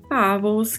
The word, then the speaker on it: pavos: Pavos, que significa Peru, sim, o famoso Peru de Natal. Peru, em espanhol, pavo. Pau com este V, né, com a pronúncia de leve som de B.